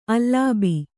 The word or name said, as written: ಅಲಬು